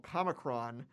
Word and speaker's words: Comicron: When I finally quit to, you know, write full time, I decided to start a website called Comicron. It does not rhyme with Omicron.